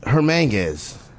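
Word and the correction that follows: Hernangómez: Hernan Gomez? – Hernan Gomez, yeah. That's how you pronounce his name? – Hernan Gomez, yeah. It's like Hernandez, but instead of the Andes at the end, it's Gomez at the end.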